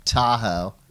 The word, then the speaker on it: Tahoe: I call it Ta'ohe. You can call it Tahoe, but if you want to sound like you know what you're doing, Ta'ohe.